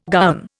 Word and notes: gowan: Indian

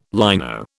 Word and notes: lino: British English

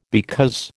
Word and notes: because: American English

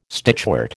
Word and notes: stitchwort: American English

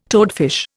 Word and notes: toadfish: Indian

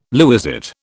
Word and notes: lewisite: British English

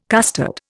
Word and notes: custard: Indian

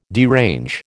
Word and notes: derange: American English

